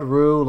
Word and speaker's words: Roux: It's spelled Rue like the Rue you put in gumbo. R-O-U-X.